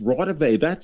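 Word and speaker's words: Raudive: But then I realized the next morning, wait a minute, he just mispronounced it. It's Rawdive.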